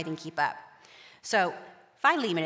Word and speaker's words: Philemon: There's Philemon. I'm losing some of them. Philemon, and I just go with Phil.